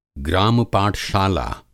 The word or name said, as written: ग्राम-पाठशाला